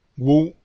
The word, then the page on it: wu: Spanish